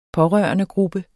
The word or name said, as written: pårørendegruppe